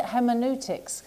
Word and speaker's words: hermeneutics: Apart from his scholarly expertise in Quranic, hermeneutics.